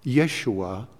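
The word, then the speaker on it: Yeshua (ישוע): Jesus is pronounced Yeshua.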